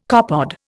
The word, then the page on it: copepod: Indian